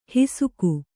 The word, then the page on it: ಹಿಸುಕು: hisuku